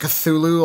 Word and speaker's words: Cthulhu: We pronounce it Cthulhu, although in the story it's like... It's like this weird guttural noise.